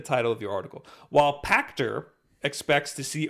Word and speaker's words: Pachter: Dr. Serkan Toto, CEO of Kantan Games, that is not how you pronounce that, and Michael Patcher, analyst. What now? – Pachter. Michael Pachter.